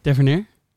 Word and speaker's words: Tavernier: Hoe zou jij dat uitspreken dan? Tavernier? Ja. ja. Dat is beter.